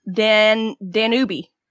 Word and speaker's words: Danube: So it was Yukon, Danube, and Mekong. – Oh, okay. – Vietnam. Did you say Danube? – Danube. D-A-N-U-B-E.